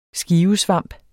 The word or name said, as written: SKIVESVAMPE